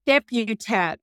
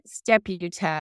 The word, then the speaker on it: Stepputat: Stepputat. Arlene Stepputat.